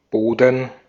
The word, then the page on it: soil: /sɔɪl/